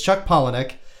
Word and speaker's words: Palahniuk: Very hard to spell, very hard to pronounce, but I heard him say it. It's Chuck Palahniuk.